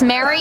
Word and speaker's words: Meri: It's Mary, but it's true. It's spelled M-E-R-I, Mary, M-E-R-I, Amber, A-M-B-E-R.